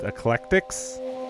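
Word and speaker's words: Iklektix: I think it's pronounced Eclectics. It's I-K-L-E-K-T-I-X.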